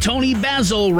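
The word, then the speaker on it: Toni Basil: At 8:36, let's get back to the tunes with Tony Basil, rhymes with dazzle.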